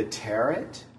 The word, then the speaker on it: tarot: Most people, if you say, I read the tarot, they say, you read the tarot? – Yes. – Right? Rhymes with parrot.